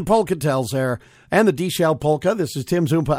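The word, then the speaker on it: Deeshell: That's D-E-E-S-H-E-L-L. The D-Shell Polka. And D rhymes with B.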